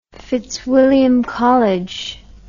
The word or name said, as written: fitzwilliam college